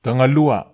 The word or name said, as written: tagalua